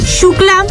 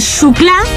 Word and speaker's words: Xuclà: Calle Xuclà. Se escribe con X. Calle Xuclà. – Como Xavier. – Como Xavier. O xilófono.